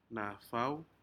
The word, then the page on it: nafao: Aneityum